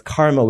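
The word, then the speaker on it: kārma: And then the Karma Mala, which creates, it's, it's not karma, it's karma with a long A, which means the agency, the sense of agency.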